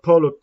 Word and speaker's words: Paulo: Polio, or Polio, I said that wrong, you kept saying it kind of things. But Paulo Costa versus Rock or Lockhold.